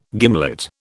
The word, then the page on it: gimlet: British English